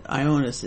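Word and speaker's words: Ioannis: Ionis Metaxas, Ionis is, that's how you pronounce it, is John in Greek.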